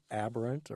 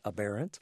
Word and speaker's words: aberrant: It used to be referred to as aberrant, or some people pronounce it aberrant.